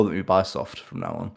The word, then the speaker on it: Ubisoft: I just hate Ubisoft, which is pronounced Ubisoft, and I need to remember that.